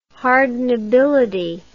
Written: hardenability

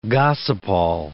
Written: gossypol

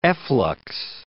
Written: efflux

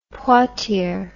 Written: Poitier

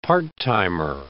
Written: part-timer

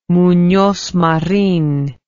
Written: munoz marin

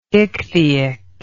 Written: ichthyic

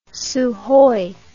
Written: sukhoi

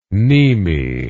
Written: mneme

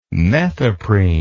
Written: methoprene

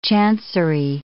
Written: chancery

